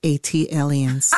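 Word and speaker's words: ATLiens: That is pronounced Atelians. – Ah, thank you. – Like Atlanta alien, Atelian.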